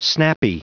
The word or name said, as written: snappy